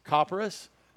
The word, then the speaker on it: Copperas: Copperas.